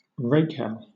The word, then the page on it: rakehell: British English